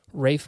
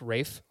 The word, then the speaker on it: Ralph: Naomi Harris, Jeffrey Wright, Christoph Waltz, and Ralph Fiennes. Did you know that it is pronounced Rafe? Rafe.